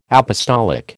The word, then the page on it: apostolic: American English